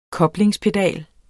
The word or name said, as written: koblingspedal